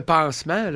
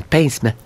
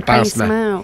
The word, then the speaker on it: pincement: Bon, on est rendu, c'est comme un pansement, là. C'était mal prononcé, c'est un pincement. – Ah, mais quand on se pince, là, un pincement.